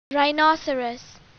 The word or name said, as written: rhinoceros